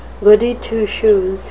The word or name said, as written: goody two-shoes